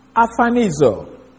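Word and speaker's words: Orphanizo (ἀπορφανίζω): Orphanizo. Sorry. That means A-P-O-P-H-A-N-I-Z-O. Well, let me spell it one more time. A-P-O-R-P-H-A-N-I-Z-O.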